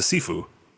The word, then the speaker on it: Shifu: Is it pronounced Shifu? – You can pronounce it both. – Okay. This one's even written Shifu, S-H-I-F-U. Like you can use both. Shifu is a more traditional pronunciation.